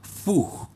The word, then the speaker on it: Fugs: Vamos a escuchar unos archivos de los Pug. Fug, se escribe F-U-G-S.